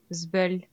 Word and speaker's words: zbeul: Alors à Lyon, voici comment ça se prononce, Zbul. Et à Toulouse. – Zbul. – Zbul. Et vous, c'est comment euh, alors moi je suis lyonnais, donc euh, je suis très triste de le dire, la seconde prononciation est plus correcte.